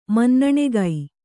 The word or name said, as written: ಮನ್ನಣೆಗೈ